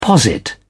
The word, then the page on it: posit: British English